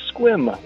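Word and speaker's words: Sequim: I was calling to respond to Rich out of Squim, Washington, and I hope he's thrilled I've said it correctly.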